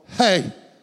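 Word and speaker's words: He: But in the Hebrew, it was called Hey, H-E-H, show slide number one. There it is. That's how you would pronounce the fifth letter of the alphabet.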